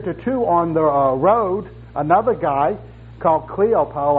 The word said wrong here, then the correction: Cleopas: I think is how it's pronounced, Cleopas.